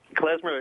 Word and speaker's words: Klezmer: Klezmer.